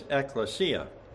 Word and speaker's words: ekklesia: The Greek word for church is ekklesia. And I'll spell that for you. It's E-K-K-L-E-S-I-A. E-K-K-L-E-S-I-A. Ekklesia.